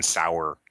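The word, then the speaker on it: Sower: Oblivion Sour can just take them. – Thank you for pronouncing it sour the second time.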